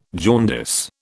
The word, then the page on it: jaundice: British English